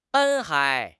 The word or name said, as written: 斑